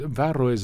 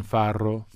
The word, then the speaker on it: farro: It is slightly ridiculous to say it correctly in Italian, farro. – I think it's fun to say that. – Okay, can say it. – Farro. – There you go.